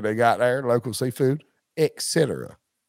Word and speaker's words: et cetera: How do you say the full version of ETC period comma? – Et cetera, yes. Not et cetera.